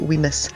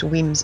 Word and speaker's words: Wemyss: So it's spelt W-E-M-Y-S-S. Looks like Weemus, but it's pronounced Weems Bay.